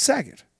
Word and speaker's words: Saget: It's Saget. Rhymes Saget. With, rhymes with things like faggot.